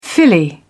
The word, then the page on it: filly: British English